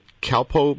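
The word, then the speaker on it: Kalpoe: And then people had seen her leave with the three suspects, Vandersloot and is it Calpo brothers from Suriname? Is that how you pronounce their last name? – Yes, that's correct.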